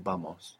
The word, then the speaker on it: vamos: Now, this is spelled V-A-M-O-S. And what you probably hear there is a B. It really depends where you are. In the southern cone around Argentina and Chile, you hear a lot more V kind of pronunciation in many places. Generally, in the Spanish-speaking world, it's like a soft B.